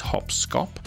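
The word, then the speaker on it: Hop Scop: I'm not mispronouncing that. It is Hopscop from 1930 also.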